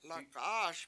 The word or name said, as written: La7A7aS